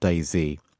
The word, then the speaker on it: DayZ: And it's very hard to talk about mods without talking about DayZ. I think it has to be pronounced like that. I don't think there's an alternative, sadly.